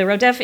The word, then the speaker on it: rodef: So the rodef is, in the American pronunciation, rodef is a pursuer, and this is someone who is seeking to end someone else's life.